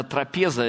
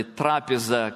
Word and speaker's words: трапеза: Кроме того, там стоял стол, по нашей русской Библии это трапеза или трапеза, кстати, ударение можно и на одно, и на другое слово, не слово, а букву ставить, да, точно.